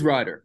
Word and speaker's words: Reider: Is it Reider or Reider? – It is Reider.